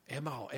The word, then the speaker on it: Emao: But they would say, Emao, Emao, that's how you say it in Japanese.